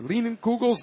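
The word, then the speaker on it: Leinenkugel's: Leinenkugels. Is that the correct pronunciation? – Correct. You can also call it Leinies, right? – Leinies. – Leinies. – Leinies or Leinenkugels, the canoe paddle kosh.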